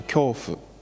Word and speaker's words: 教父: の 1 世紀から 2 世紀にかけて生きた初代教会の教父という教える父と書いて教父と読みますけれども、